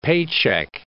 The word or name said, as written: paycheck